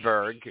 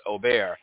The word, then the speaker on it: Åberg: And in English terms, it's spelled A-B-E-R-G. Åberg is the way we Americans like to pronounce it, but it's called he's pronounced Åberg.